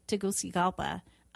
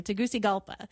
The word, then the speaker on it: Tegucigalpa: I think flying into Tegucigalpa, the capital city of Honduras. – Say that again. That's very impressive. You could pronounce that. – I hope I'm saying it correctly. Tegucigalpa.